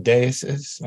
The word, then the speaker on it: deixis: Yeah, I call it deixis with a strong X. I'm not sure if that's the original way it's supposed to be pronounced. It's a Greek term originally. – Okay. – Greek is not a language that I speak. So I just pronounce it in my mother tongue, which is German.